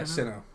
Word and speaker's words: Sinnoh: Yeah, as we're Sinnoh? – Talking. – Is that how you pronounce it? – Yeah, Sinnoh.